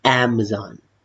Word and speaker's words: Amazon: Amazon Amazon ไม่ใช่อะนะครับแต่เป็นแอ Amazon ถ้าเป็น US อเมริกาก็จะออกเป็น Amazon